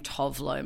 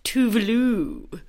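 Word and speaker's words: Tove Lo: And I've been saying Tovlo my whole life, which is so embarrassing because the Swedish pop star, but her her name is actually pronounced Tuvalu. Yeah.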